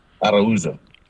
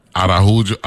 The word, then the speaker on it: Araujo: We got St. Thomas, St. John candidate for the Senate, Vernon Araujo, because it's A-R-A-U-J-O, and you've been on the show before. I want to pronounce it correctly. How do you pronounce it, sir? – Araujo. – Araujo. Araujo. – Yeah, Portuguese, actually.